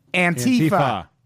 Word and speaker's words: Antifa: Antifa. Yes, that is how you pronounce it.